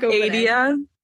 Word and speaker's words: Adia: No, my name is Adia. And like Adia.